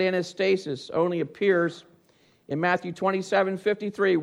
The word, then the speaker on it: anastasis: the word for resurrection is pronounced anastasis.